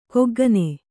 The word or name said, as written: ಕಿಗ್ಗಣ್ಣು